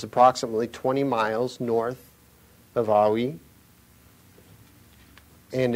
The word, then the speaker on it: Ai: This was performed after they defeated the city of Ai, as many people who speak English say, it's pronounced Awi, but it's just spelled A and I.